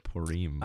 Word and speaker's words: Purim: I gotta pronounce it Purim.